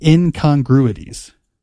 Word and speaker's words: incongruities: Can I say that right, Jesse? Incongruities. – Yeah, you said it right.